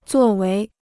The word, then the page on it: 作为: zuò wéi